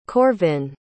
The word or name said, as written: corvin